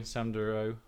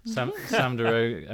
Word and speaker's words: Deroo: But yeah, the fact he has a boy, Sam Deroo. Oh, there you go. I said it correctly. Sam Deroo.